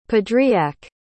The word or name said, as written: padriac